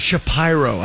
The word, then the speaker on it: Shapiro: How about Mark Shapiro? He doesn't want to be Jewish in Cleveland. It's spelled Shapiro. – He's a Cleveland guy. – He's like, no, it's Shapiro.